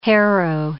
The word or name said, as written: harrow